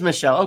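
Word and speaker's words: Míchel: It is Michelle.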